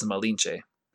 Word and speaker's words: Malinche: The Spanish name Marina would, however, have been pronounced by Nahuatl speakers as Malina. And the Nahuatl honorific suffix tsin, T Z I N, was heard and pronounced by Spanish speakers as Che. So by back formation, Nahuatl Malintzin from Spanish Marina returned to Spanish as Malinche.